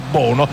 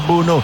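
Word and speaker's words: Bounou: ha 2 pronunce Bunu, o Bunu o Bono così come ha scritto sulla maglietta, però insomma la pronuncia in lingua marocchina è Bunu.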